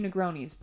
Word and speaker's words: Negroni: And they said, oh, two Negronis, please.